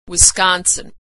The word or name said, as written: Wisconsin